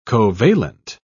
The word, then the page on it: covalent: British English